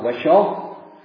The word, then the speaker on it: Wishaw: I was out visiting, I think I was doing some pastoral visiting at the time, in Wishaw. I called it Wishaw. The locals looked at me. No, they said it's Wishaw. They pronounced it Wishaw.